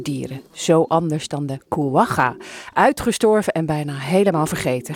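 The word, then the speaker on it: quagga: De, de Kuwaga, ik weet niet eens eigenlijk of ik het goed uitspreek. – Ja, dat spreek je helemaal goed ja. – uit. Uh, denk je van het is kwaga, maar het is kwaga.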